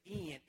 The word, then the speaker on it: Ian: I said, Daddy, it is Ian. It is spelled I-A-N, but it's pronounced E-N. Just an E and an N, Dad.